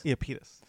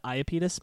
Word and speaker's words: Iapetus: Iapetus. – Some have said Iapetus, but most of the pronunciations I found said Iapetus.